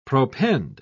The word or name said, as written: propend